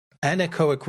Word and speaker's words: Anechoic: Anechoic room.